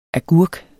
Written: agurk

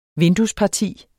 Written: vinduesparti

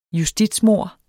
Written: justitsmord

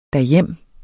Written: derhjem

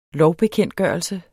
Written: lovbekendtgørelse

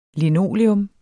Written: Linoleum

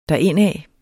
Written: derindad

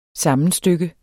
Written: SAMMENSTYK

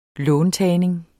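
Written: låntagning

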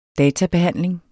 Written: databehandling

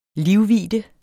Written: livvidde

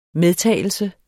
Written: medtagelse

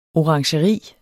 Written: orangeri

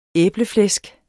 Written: æbleflæsk

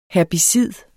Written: herbicid

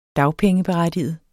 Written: berettige

